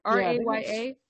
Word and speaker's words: Raya: R A Y A. I'm pretty sure it's Raya, but oh, I'm Raya.